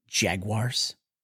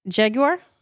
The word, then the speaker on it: jaguar: Does it talk about them shedding their skin at the end and becoming jaguars? How do you pronounce that word? – Jaguar? – You say jaguar. – I throw a little bit of the U in it because it's got one, but I try not to let it overstay its welcome.